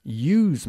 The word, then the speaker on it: ewe: I've never drunk ewe's milk, but I have drunk goat's milk. Okay. Ewe se pronuncia como you. A ewe se escribe E-W-E y es una...